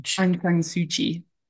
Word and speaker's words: Aung San Suu Kyi: Ansung Shi, is that right? – Aung San Suu Kyi.